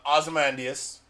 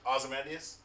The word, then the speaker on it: Ozymandias: And that is because Dr. Doom is, much like Lex Luthor and Ozymandias, the greatest. – How do you say that? – Being Ozymandias. – I say Ozymandias. – Ozymandias? That sounds good, too. – Yours is better. – Hmm.